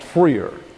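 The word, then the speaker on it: phrear: That's P-H-R-E-A-R, pronounced freer.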